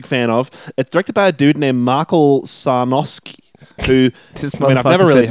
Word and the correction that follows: Michael: Michael Sarnowski. I was so thrown by Sarnowski that I completely forgot that I have to pronounce Michael correctly.